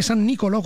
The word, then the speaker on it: San Nicolò: ponte San Nicolò con l'accento sulla O, Padova, è qua ragazzi, DDL 978 pignoramenti lampo degli avvocati con lo scudo penale senza giudice.